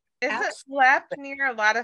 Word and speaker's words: Lemp: Lep, but it's spelled L-E-M-P. – L-E-M-P.